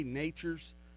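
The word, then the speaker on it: Nachurs: Well, they, you get, and let's just let everybody know that when we say Nature's fertilizer, it's not pronounced how you think it is. It's N-A-C-H-U-R-S, right? – Yes, sir.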